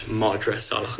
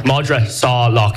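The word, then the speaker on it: Madra Salach: Sorry, it's Madra Salah. – Madra. – I was asked to... – Have I been saying it wrong the entire time? – I don't know. I didn't. That's the first time I've caught it. I was asked to intervene with pronunciation. – You are. I told you. – We haven't made it easy on you with Irish. Madra Salah. If you think of Salah.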